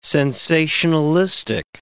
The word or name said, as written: sensationalistic